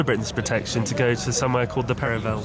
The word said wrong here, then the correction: Peveril: It's Peveril of the Peak, not Perivale, as they mispronounced just then.